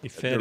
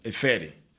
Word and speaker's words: Ifedi: Ifedi, the right tackle. Ifedi. That's it. Yep. Ifedi.